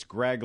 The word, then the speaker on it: Graig: But uh, Graig Kreindler, it's Graig like uh, Graig Nettles, the uh, former Yankee player. It's spelled like that, G R A I G.